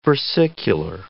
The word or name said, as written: versicular